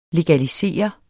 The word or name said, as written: LEGALISERET